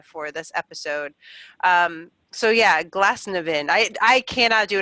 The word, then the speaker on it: Glasnevin: Glasnevin, that's how we decided we're going to pronounce it, Glasnevin Cemetery in Dublin.